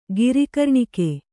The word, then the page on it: ಗಿರಿಕರ್ಣಿಕೆ: kannada